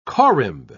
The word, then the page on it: corymb: British English